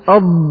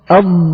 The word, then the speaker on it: ض: فما النطق الصحيح لها أن تقول أض أض، وهي حرف هناك فرق بينها وبين الضاء، والخطأ الفاحش أن تنطق الضاد ضاء.